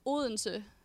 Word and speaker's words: Odense: Odense? – Odense.